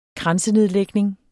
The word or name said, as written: kransenedlægning